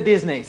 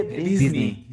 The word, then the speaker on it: Disney: Pero no se dice Disney, se dice Disney.